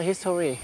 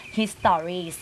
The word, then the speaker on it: history: I will tell you about the history เด็กไทยสมัยนี้จะออกเสียงอย่างเงี้ยค่ะตรงตัว history ซึ่งไม่ได้ค่ะต้องอ่านออกเสียงว่า history น